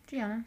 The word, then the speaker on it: Jeana: So, his sister Gianna, I think is how you say it, J-E-A-N-A. – Yeah, Gianna, sounds right.